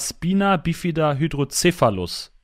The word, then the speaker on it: Spina Bifida Hydrocephalus: Und du hast seit Geburt einen Gendefekt, deswegen sitzt du in einem Schlitten, und zwar Spina Bifida Hydrocephalus. Ist das richtig ausgesprochen? – Ja, das ist korrekt ausgesprochen, ja.